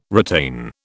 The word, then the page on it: retain: British English